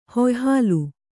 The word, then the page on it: ಹುಯ್ಹಾಲು: huyhālu